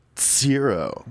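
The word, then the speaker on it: Tzero: I think it's pronounced zero.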